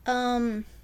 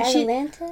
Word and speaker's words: Atalanta: I forget how to say it, but there's, like, this Greek hero, Atalanta, and Atalanta? – She, it's, like, A-T-A-L-A-N-T-A.